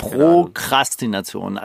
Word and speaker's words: Prokrastination: Glaube ich ohne R, oder? Ich, ich habe es immer mit, mit zwei Rs ausgesprochen, vielleicht war es falsch die ganze Zeit. Prokrastination. Also es ist anders als bei Aristokratie, da kommt nämlich kein R nach dem T.